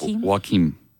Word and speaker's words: Joaquim: Joaquim. – Joaquim? – Yep. – It's spelled like Joaquin. It just has an M at the end.